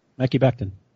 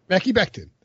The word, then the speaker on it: Mekhi Becton: What did you think about Wilson giving your Browns, or the Browns, is it, how do we say it, Mekhi Becton? Is that right? – Mekhi Becton. – Mekhi Becton. That's it.